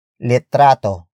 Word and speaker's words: litrato: And that is litrato. Litrato. Litrato. Litrato is spelled as L-I-T-R-A-T-O.